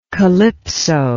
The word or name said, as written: kalypso